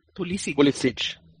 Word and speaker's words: Pulisic: Poliski, or what's his name? How you pronounce it? – Pulisic.